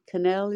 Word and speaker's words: Cannell: So you began your career as a casting intern with Stephen Cannell, is it? – Or it rhymes with channel. That's how you remember.